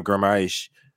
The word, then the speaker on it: Guimarães: Since moving Joelinton back to that box-to-box midfielder role on the left-hand side of Bruno Guimarães, try to say his name 6,000 times.